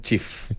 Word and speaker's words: Cheaf: Chief, correcto. C-H-E-A-F, pero se pronuncia Chief.